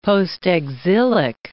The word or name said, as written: postexilic